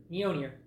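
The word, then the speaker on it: Mjölnir: Our special, how do you pronounce it again? Is it Mjolnir? – Mjolnir.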